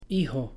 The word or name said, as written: hijo